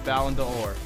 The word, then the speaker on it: Ballon d'Or: Next, Cristiano Ronaldo wins his fifth Ballon d'Or. Did I say that right? – Yep, Ballon d'Or.